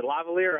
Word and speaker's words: lavalier: Is it lavalier or how do you say it? – That's the way you pronounce it.